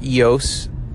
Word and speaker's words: EOS: EOS, or EOS is how it's pronounced.